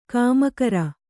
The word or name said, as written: ಕಾಮಕರ